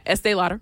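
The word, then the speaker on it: Estée Lauder: Yeah, Estee Lauder.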